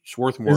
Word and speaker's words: Swarthmore: I think the first R is not pronounced, actually. It's Swarthmore. – Actually, if you're a Swatty, as they call themselves, you actually expect to hear the first R, Swarthmore.